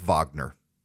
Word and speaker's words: Wagner: Prigozhin, who is the head of the Wagner group, Wagner, it's spelled like Wagner, it's pronounced Wagner.